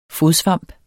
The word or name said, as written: fodsvamp